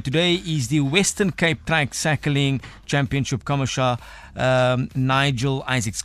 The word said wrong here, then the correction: commissaire: Commissaire. What's the correct pronunciation? – Commissaire. – Commissaire. That is French, uh, am I correct? – Correct.